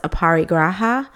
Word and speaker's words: aparigraha: This one is a restraint, and it is pronounced aparigraha, which is translated to non-possessiveness.